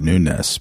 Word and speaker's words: Nunes: And they're like, I hate to be pedantic, but it's actually Nunes because of his 75% Portuguese background.